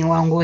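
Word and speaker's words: Wangu (광주): ¿cómo se pronuncia? Sí, Wangu está correcto.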